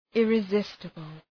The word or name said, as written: irresistible